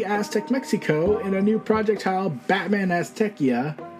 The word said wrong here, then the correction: Azteca: I mean, Azteca Okay, I'm not pronouncing the Spanish title.